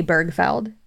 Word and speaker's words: Bergfeld: So today we're going to be talking about the murder of Paige Bergfeld, or is it Birchfeld? – I don't know. I'd say Bergfeld. – That's what I thought too. That's the way it's spelled.